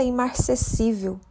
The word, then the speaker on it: imarcessível: com acento gráfico na sílaba tônica si. O seu significado está associado àquilo que não murcha, repleto de vigor, por exemplo, uma planta imarcessível.